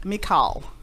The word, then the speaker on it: Michal: I think that's how you say it. Michal. It's with a K. Michal.